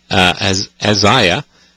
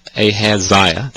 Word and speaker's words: Ahaziah: which is talking about the age of Ahaziah, Ahaziah, depending upon which way you want to pronounce it.